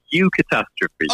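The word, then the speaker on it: eucatastrophe: And this is brilliant because it actually looks like it's pronounced EU catastrophe. – Yes, no. I've been trying to explain this one and it's always I'm like, how can I pass this off as not saying EU catastrophe? But, yeah, it's EU catastrophe. – Oh, okay. – So it's kind of pronounced like that.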